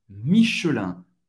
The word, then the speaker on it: Michelin: Michelin. Pronunciado rápido em francês nativo seria Michelin. O E desaparece. Michelin. Michelin. Michelin. Michelin. IN em francês, mais uma vez, o som do AN.